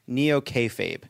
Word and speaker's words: neo-kayfabe: In your book, you describe the Republican Party's approach to reality as neo-kayfabe.